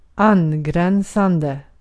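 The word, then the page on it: angränsande: Swedish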